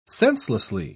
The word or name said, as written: senselessly